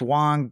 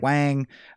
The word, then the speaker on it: Wang: Their name's pronounced Wong, but everyone says it Wang.